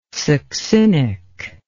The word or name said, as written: succinic